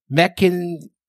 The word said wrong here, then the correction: Mechanicsburg: Pennsylvania. – Pennsylvania. – Mechanicsburg.